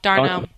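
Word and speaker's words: d'Arnaud: Tyler Flowers and Travis Darno. How do we pronounce it? $2 in? – They're giving you the thumbs up. Darno. – Darno.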